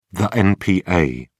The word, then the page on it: npa: British English